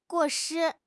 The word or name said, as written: 过失